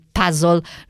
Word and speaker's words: puzzle: È vero, puzzle è una parola inglese, si pronuncia appunto così. Io a volte dico puzzle perché così mi sembra che in italiano forse ormai siamo abituati a dire così.